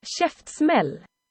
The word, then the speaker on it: Käftsmäll: The next band is Kaftsmal or? – Käftsmäll. – Käftsmäll. I had that incorrect, but that's what it looks like. Kaftsmall. I've never Google translated that before. – Käftsmäll. – So I've been saying it wrong for quite a few years because I've been listening to this band for a few years. Anyway, the name translates to Jaws, you know, like the shark.